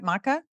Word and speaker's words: maca: Did I say that right? Maca? – Yes. Not matcha. – Not matcha. Yeah.